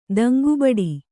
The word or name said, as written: ದಂಗುಬಡು